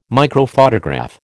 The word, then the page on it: microphotograph: American English